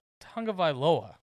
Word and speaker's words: Tagovailoa: What shocked me in this little bit, Travis, is that the top performing quarterback was Tua Tagovailoa.